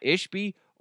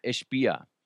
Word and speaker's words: Ishbia: I've heard people pronounce it two different ways. It's either Matt Ishby or Matt Ishbia.